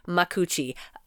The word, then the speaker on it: Makuuchi: Makuuchi.